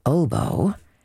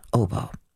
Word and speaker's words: oboe: Hard to pronounce and play, the oboe, with cultured folk it rhymes with doughboy, though many an intellectual hobo insists that we should call it oboe.